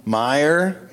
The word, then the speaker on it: Meijer: What's the name of that place that they're from? – Meyer. – That's how you pronounce it? – Yeah.